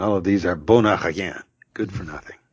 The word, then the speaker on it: bon appétit: And no pronouncing that final T. Bon appetit.